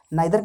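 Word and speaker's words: neither: नाइदर का मतलब British हो जाएगा नीदर का मतलब अमेरिकन हो जाएगा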